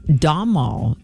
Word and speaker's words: Domhnall: Domhnall is how it's pronounced. I just checked.